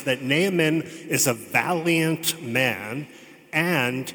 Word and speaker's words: Naaman: Very good pronunciation, by the way. Naaman.